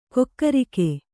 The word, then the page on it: ಕಕ್ಕರಿಕೆ: kakkarike